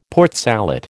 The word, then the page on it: Port Salut: American English